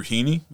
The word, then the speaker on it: Heaney: And then they signed Andrew Heaney. Am I mm-hmm. saying that right? – Yep.